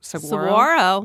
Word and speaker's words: saguaro: And then you've got like a very cute, bright color Arizona desert landscape with very pretty cactus blossoms and saguaro. Is that how you say it? Saguaro. – Saguaro.